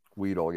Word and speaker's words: Weidl: And I'm reading from an article from Mark Cabali, and he reported that, and correct me if I'm pronouncing his name wrong, Andy Weedle. – Weedle, yeah.